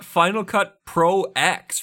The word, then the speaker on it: Final Cut Pro X: Do you want Final Cut Pro X for $300? – That's pronounced 10, by the way, but no, I don't.